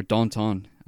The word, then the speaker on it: Danton: I watched Danton, or Danton, as it's pronounced in French.